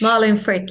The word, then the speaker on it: Malin Frick: Malin Frick.